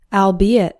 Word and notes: albeit: al - BEE - it

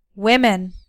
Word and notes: women: American English

